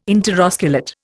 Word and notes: interosculate: Indian

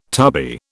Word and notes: tubby: British English

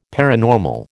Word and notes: paranormal: American English

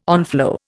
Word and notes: onflow: Indian

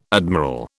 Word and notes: admiral: British English

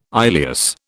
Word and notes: ileus: British English

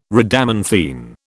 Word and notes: Rhadamanthine: British English

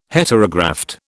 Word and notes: Heterograft: British English

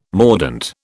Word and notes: mordent: British English

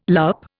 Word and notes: loupe: Indian

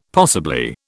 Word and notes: possibly: British English

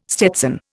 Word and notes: stetson: Indian

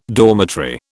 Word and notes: dormitory: British English

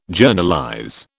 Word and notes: Journalize: British English

